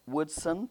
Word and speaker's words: Woodson: I'm Joanne Woodson Thompson, J-O-A-N-N, all together, no capital A, Woodson, W-O-O-D-S-O-N, Thompson, T-H-O-M-P-S-O-N.